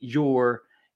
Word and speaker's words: Jeor: We use the Mifflin St. Jour. It's spelled J-E-O-R.